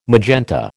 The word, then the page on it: magenta: American English